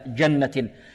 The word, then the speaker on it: جنة: من نطقها كما جاءت عن السلف، نعطيها صفة شدة، لا نقول في جنة في جنة في جاء أج، لا، في جنة جنة، هكذا جنة.